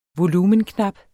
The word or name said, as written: volumenknap